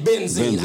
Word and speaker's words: benzene: It addresses methane benzoene. I hope I did benzene. I'm sorry. – Well, methane benzene. I pronounced that wrong.